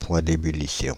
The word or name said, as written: point d'ébullition